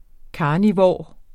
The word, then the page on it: carnivor: ˈkɑːniˌvɒˀ; ˈkɑːniˌvoˀɐ̯